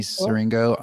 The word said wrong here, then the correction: Zigrino: on Conan. – Zigrino, you got to do that again. I was like, I, I said that wrong.